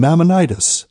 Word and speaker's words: Maimonides: named Maimonides, or Maimonides. I've heard it pronounced both ways.